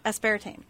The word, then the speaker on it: aspartame: Because the next ingredient on our hit list is aspartame. Which is in diet soda. – Aspartame Thank you for saying it correctly.